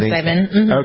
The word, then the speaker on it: psilocybin: And what you're better at pronouncing it, is it psilocybin? Is that, am I pronouncing it right? – Yes, psilocybin.